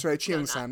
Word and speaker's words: Cheongsam: It is a Cheongasm. – Cheongsam, not Cheongasm. It's not a Cheongasm, it's a Cheongsam.